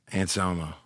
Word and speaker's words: Anselmo: Anselmo, the Pantera guy. – Anselmo.